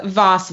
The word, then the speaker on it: Voss: Voss with the hard Voss. V.